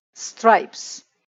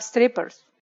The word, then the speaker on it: stripes: Spelling stripes. Sometimes we are joking and we pronounce this word little different because there is problem too. Many students, they have strippers. That is absolutely wrong.